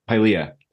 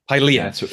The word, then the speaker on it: Pylea: And you've mentioned, how do I say it? Pylea? Is that how you said it? Or Pylea. – Pylea. – It's named after a plant.